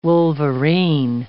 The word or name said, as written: wolverine